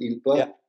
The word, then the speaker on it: ILPA: That is very useful because what I do think, and this is what people from ILPA, would you pronounce it ILPA? – Yeah, that's right.